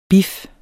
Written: BIFFERNE

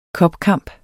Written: cupkamp

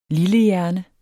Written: lillehjerne

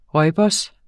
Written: rooibos

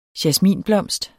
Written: jasminblomst

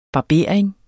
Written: barbering

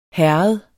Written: herred

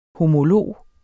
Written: homolog

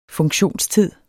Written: funktionstid

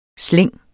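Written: Slængene

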